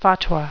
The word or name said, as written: fatwa